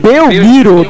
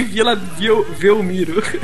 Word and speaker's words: Belmiro: Como é que se pronuncia? Vila Velmiro ou Vila Belmiro? – Belmiro, doente. – Eu sempre falei Vila Velmiro.